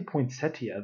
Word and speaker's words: poinsettia: And so, like, all, of I sudden, I was like, don't say poinsettia. The I is silent. And finally, I learned that that's that's totally wrong.